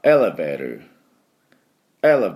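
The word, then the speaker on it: elevator: ですからエレベーターエレベーターというふうにエのところにアクセントがあります。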